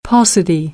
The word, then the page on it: paucity: American English